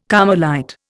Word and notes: Carmelite: Indian

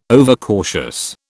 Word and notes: overcautious: British English

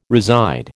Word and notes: reside: American English

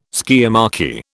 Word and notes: skiamachy: British English

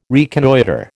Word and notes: reconnoitre: American English

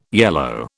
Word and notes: yellow: British English

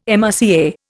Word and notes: MRCA: Indian

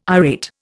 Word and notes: irate: Indian

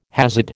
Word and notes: Hasid: American English

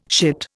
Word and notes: shit: Indian

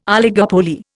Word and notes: oligopoly: Indian

